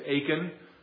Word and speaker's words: Achan: Joshua chapter 7, that is the account of Achan, or as some pronounce it, Achan.